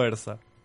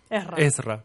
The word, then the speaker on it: Esra: y, me está gustando esto de a dónde está yendo Ersa. – Esra.